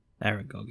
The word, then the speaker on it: Aragog: Aragog, am I saying it right? The big fucking spider? Is it Aragog or Arangong or... – Aragog is fine.